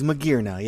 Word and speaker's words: Magearna: Is it Magearna? – It is Magearna, yeah.